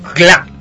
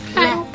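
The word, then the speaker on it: küla: Claro, porque eso es muy importante que el otro día nos hicimos esa aclaración que es como que se escribe con una U, ¿no? Es como que se escribiera clula, pero la U no suena, es como un cla.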